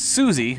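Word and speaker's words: SUSE: The IT guy here has Suzy, which is spelled wrong. – Like, right, it's S-U-S-E.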